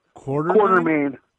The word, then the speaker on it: Quartermain: It's Jeff Jarrett taking on Buck Quartermain. – Quartermain. – Quartermain? – Yeah, like, yes. like say quarter and then say main.